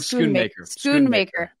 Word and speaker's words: Schoonmaker: Luke Schoonmaker.